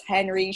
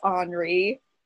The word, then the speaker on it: Henri: except that Prince Henry should be Prince Henri, and King Francis should be King Francois.